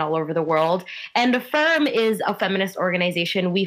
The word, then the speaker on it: Af3irm: So you work for the Coalition Against Trafficking in Women, CATW, and you also are a member of Af3irm.